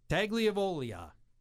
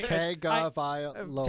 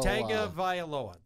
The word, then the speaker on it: Tagovailoa: I think I butchered his name. – I'm not going to correct you, though, because I would do it, too. – Tagovailoa. – Tagovailoa. That's right.